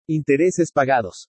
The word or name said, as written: INTERESES PAGADOS